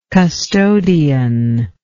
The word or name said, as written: custodian